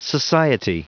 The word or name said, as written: society